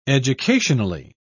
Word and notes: educationally: British English

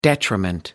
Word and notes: detriment: American English

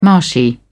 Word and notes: marshy: British English

